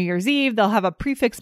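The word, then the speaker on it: prix fixe: So I pronounce this prefix. Is that right? – Me too. – Fixed price. – I think that's right.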